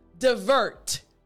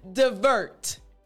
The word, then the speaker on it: divert: Divert. I said die, but it's duh. Again. Divert.